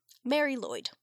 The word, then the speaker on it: Marilwyd: I just want to tell you how this is spelled, because it's Welsh. M-A-R-I-L-W-Y-D. Mary Lloyd.